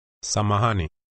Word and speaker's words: Samahani: Samahani.